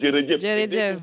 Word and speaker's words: jërëjëf: Comme on le dit, euh, j'espère que je vais bien le prononcer, Djerejef. – Et... Ah, ça, ça fait plaisir. – Djerejef.